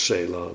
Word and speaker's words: Sela: Nou ja, dan in het Arabisch, maar dan met onze Romeinse letters staat er gewoon Sela, of Sala staat er geloof ik op de... En dan spreek je het nog in het Engels uit als Sela, dus het blijft hetzelfde.